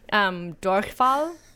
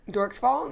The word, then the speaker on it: Durchfall: Durchfall. Have you ever heard of this? – Durchfall? No. – Well, you wouldn't because you're mispronouncing it once again.